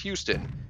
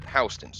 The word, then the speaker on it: Houston: I don't know how they pronounce that in Missouri, because I know in Texas, this city, Houston is pronounced Houston. In New York City, though, there is a street spelled the same exact way, but everyone calls it Houston Street.